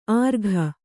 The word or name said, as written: ಆರ್ಘ